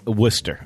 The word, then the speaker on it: Worcester: How do you pronounce that? – Worcester, I believe.